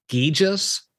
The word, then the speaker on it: Gijia: In your book, you use the term, if I pronounce this correctly, Gijas. G-I-J. – Yeah. – Explain that for our listeners. – Yeah. G-I-J-I-A.